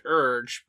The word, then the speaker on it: Erghe: It's pronounced Urge, but we'd have to come up with a fun spelling for that. – Urge. E-R-G-H-E.